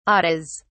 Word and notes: ates: British English

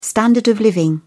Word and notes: standard of living: British English

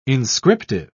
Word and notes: inscriptive: British English

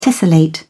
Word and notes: tessellate: British English

